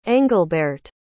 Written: englbehrt

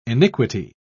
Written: iniquity

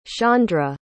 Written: shaundre

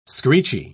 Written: screechy

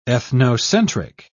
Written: ethnocentric

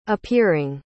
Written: appearing

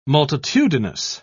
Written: multitudinous

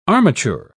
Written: armature

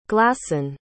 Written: glassen

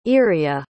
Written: iria

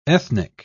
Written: ethnic

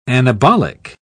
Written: anabolic

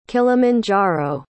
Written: kilimanjaro

